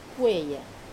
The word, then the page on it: peya: wamesa